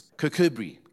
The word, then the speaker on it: Kirkcudbright: The town nearby, the way they pronounce it is Kirkcudbright. Kirkcudbright. And if you say it, you know, the normal way, it's Kirkcudbright. Kirk is like our Afrikaans, kerk. In Scottish, kirk is a church.